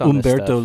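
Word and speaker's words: Humberto: It was Umberto Leon. – Umberto, the silent H.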